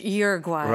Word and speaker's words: Uruguay: Yeah, American English Uruguay.